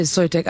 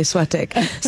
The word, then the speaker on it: Soitec: I pronounced it Soitec earlier, which is really wrong given that I'm French, so I should say Soitec.